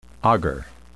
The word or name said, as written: agar